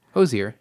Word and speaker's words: Hozier: Is that how you say it? I've been saying Hozier. – Hozier, yeah.